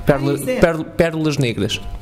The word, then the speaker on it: Perlas Negras: and it was him and Perles Negras. How do you say that? – Perles Negras, yeah. – Perles Negras. – Sorry, no, Portuguese is difficult, yeah. Perlas negras.